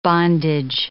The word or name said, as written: bondage